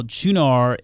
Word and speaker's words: Qunar: It's Qunar. It's spelled Q-U-N-A-R, but I believe it is pronounced Qunar.